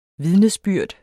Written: vidnesbyrd

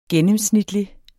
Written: gennemsnitlig

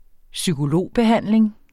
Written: psykologbehandling

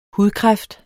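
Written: hudkræft

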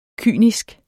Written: kynisk

